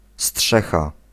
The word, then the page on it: chaume: France; /ʃom/